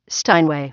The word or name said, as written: Steinway